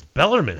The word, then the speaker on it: Bellarmine: It's Bellarmine. How's there any question? – Because it reads like everybody says Bellarmine. Like everybody last year, M-I-N-E, they read it, they say Bellarmine.